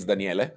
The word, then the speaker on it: Daniele: So my name is Daniele, as you said. You pronounce correctly. It's not trivial.